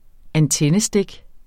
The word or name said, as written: antennestik